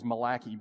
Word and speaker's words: Malachi: Actually, the Hebrew pronouncing is Malachi, but people make fun of you if you say it that way to teachers, so just be careful. We're in Malachi.